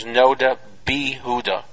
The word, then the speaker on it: Noda B'Yehuda: The correct pronunciation is Noda B'Yehuda, not B'Yehuda, which is a common error.